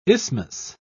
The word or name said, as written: isthmus